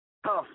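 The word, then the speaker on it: Hough: Huff. That's how I pronounce her name.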